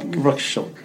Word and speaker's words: Rorschach: the Voracious test, or the inkblot test. – Oh yeah. The butterflies. – Yeah, so you did a Rorschach test.